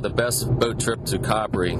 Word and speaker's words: Capri: What is the real pronunciation of that island? – Italian is Capri. – Okay, well, you're Italian. It's Capri.